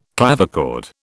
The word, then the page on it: clavichord: British English